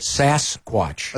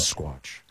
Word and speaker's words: Sasquatch: Am I pronouncing it right now? – Yeah, you did. – Sasquatch. – That's right. That's correct. – Not Sasquatch. Yeah. That sounds a little too East Coast.